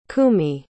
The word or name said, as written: Kumi